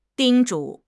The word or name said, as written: 叮嘱